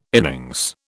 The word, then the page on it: innings: British English